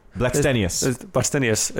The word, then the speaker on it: Blackstenius: Yeah, Stina Blackstenois? – Blackstenius. – It's sounds like it's spelled.